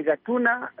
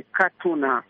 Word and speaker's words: Gatuna: Kwa upande wa Rwanda unaitwa ni Gatuna, lakini kwa upande wa Uganda unaitwa ni Katuna, kutokana na matamshi na lugha mbalimbali za nchi hizi mbili.